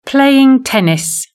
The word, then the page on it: playing tennis: British English